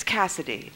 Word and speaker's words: Kasidie: But this is K-A-S-I-D-I-E. – Exactly, yeah. – Yes, and so, anyway, okay, so, but it's Cassidy, okay.